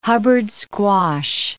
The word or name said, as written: Hubbard squash